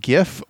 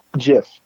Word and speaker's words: GIF: Pronunciation, is it GIF or JIF? – Jif. – All right. It's Jif.